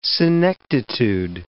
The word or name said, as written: senectitude